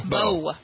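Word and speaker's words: Bowe: Is it Bowie or Bo? – Bo.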